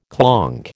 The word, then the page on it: clonk: American English